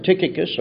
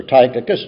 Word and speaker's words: Tychicus: Tychicus or Tychicus, depending on the pronunciation guide you use.